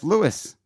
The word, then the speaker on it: Louis: It's spelled L-O-U-I-S-C-K. – Yeah, but that's Louis. – It's not, though. It's pronounced Louis.